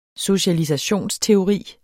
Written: socialisationsteori

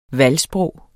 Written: valgsprog

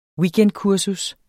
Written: weekendkursus

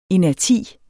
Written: inerti

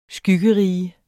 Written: skyggerige

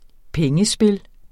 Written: pengespil

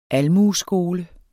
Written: almueskole

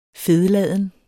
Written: fedladen

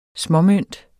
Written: småmønt

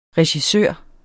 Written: regissør

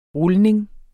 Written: rulning

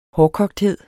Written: hårdkogthed

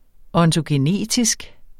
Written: ontogenetisk

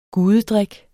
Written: gudedrik

